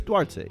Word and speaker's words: Duarte: Yeah, Chris Duarte.